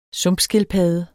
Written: sumpskildpadde

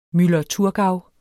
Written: muller

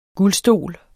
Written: guldstol

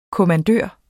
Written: kommandør

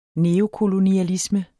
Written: neokolonialisme

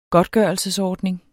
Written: godtgørelsesordning

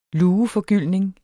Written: LUEFORGYLDT

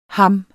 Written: ham

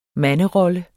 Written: manderolle

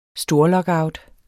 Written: storlockout

